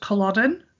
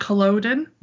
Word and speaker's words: Culloden: So I've had a little bit of feedback from someone who told me that it's pronounced Culloden, the Battle of Culloden-more, not Culloden, my American. So, twang to it.